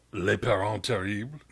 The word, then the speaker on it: Les Parents Terribles: Les Parents Terribles.